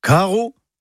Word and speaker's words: karv: Eh bien, aujourd'hui, le papa dans la famille, car Caro, en écriture bretonne moderne, c'est le majestueux Serelaf. Caro, K-A-R-V. Oui, alors le V, enfin, se prononce O. C'est comme ça, faut l'apprendre.